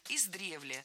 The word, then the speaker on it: издревле: Издревле, неверно. Правильный вариант – издревле. В этом слове ударение ставим на второй слог. Для запоминания предлагаю держать в уме однокоренное существительное с предлогом. Из древности, издревле.